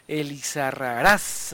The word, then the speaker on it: Elizarraraz: Acento Elizarraraz, acento en la última.